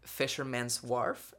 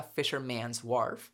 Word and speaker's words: Fisherman's Wharf: Fuimos a restaurantes súper buenos y también fuimos al Fisherman's Wharf. Esto es que lo pronuncio con mucha conciencia porque lo decía mal en inglés. Yo decía Fisherman's Wharf, pero es Fisherman's Wharf.